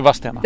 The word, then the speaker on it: Vadstena: Ja, det är Vadstena. – Det är Vadstena. – Det är rätt. – Vadstena. Man uttalar inte det.